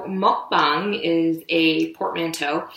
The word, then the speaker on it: mukbang: And so apparently this was popularized in South Korea, and I may have the pronunciation wrong. You can correct me. It's apparently called mukbang.